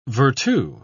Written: vertu

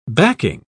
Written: backing

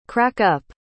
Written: crack-up

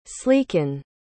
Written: sleeken

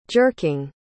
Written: jerking

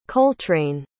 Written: coltrane

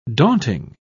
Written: daunting

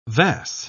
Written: vas

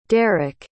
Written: deric

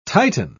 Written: tighten

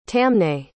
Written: tamnais

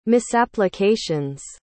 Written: misapplications

